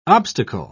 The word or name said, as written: obstacle